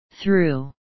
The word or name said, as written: through